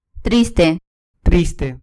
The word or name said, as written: triste